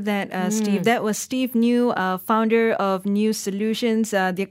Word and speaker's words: NGEU: It's spelled N-G-E-U. – Yes. – Okay, because everybody's going to start searching for new. And it's pronounced new, N-G-E-U Solutions.